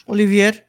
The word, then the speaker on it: Olivier: And I think the end, she's making love with Olivier. Is that how you say it? – Olivier, yeah.